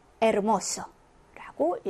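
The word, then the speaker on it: hermoso: 자, 아체가 이제 처음 스페인어를 접하는 학습자들은 많이 실수를 하는데, 이제 영어의 H다 보니까 히읗 발음이 날 거라고 생각하지만, 스페인어에서는 묵음입니다. 다시 말해서 발음되지 않습니다. 있지만 발음하지 않아요. 그러면 어떻게 읽으시면 될까요? 맞아요. 암브레라고 읽어주시면 되고, 에르모소라고 읽어주시면 됩니다. 그래서 아체는 묵음이다라고 생각하시고요.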